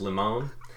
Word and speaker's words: limon: Instead of that, it's called a limon. It looks like a lemon, but it's pronounced limon, and it's like a personal assistant.